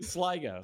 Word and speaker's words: Sligo: Do you think Silgo has a chance this season? – Sligo. – Sligo? Is that how you pronounce that? – Oh, boy.